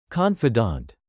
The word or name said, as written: confidant